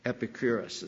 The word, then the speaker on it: Epicurus: Epicurus, the Greek philosopher, Epicurus is more accurate pronunciation, he said this.